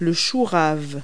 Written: kohlrabi

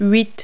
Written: eight

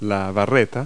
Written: crowbar

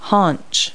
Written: haunch

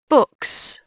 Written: books

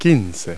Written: fifteen

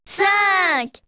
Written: cinq

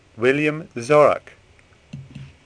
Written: Zorach

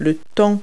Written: weather

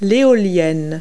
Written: windmill

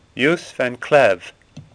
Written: Cleve

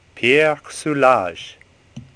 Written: Soulages

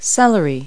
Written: celery

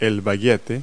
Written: baguette

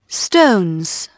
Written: stones

